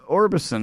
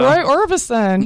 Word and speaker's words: Orbison: We're going to do some Roy Orbison now. I said that right? – Yes. – Orbison? I mean, I've heard it, but I never heard his name said. – Yeah, so. Roy Orbison.